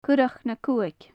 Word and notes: currac na cuthaig: gaelic

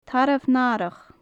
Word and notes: tarbh nathrach: gaelic

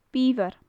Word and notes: biobhair: gaelic

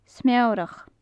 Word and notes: smeorach: gaelic

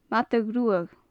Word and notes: madadh-ruadh: gaelic

